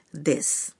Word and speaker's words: です: মনে রাখবেন জাপানি লিপিতে দেসু লেখা হলেও এর সু অংশের উকার অনুচ্চারিত থাকে তাই এই শব্দটির উচ্চারণ হবে দেশ এবারে রয়েছে নিজের পরিচয় দেওয়া আরেকটি সংলাপ